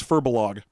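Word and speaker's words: Firbolg: Oh, the Firbolgs. – Now, we are calling them Firbolgs, but this actually has a pronunciation guide for them, and it says it's Firbolog.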